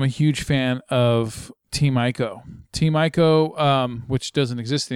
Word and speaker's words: Ico: I think it's Team ICO. – They pronounce it Ico because the first game that they made for the PlayStation 2 was called Ico.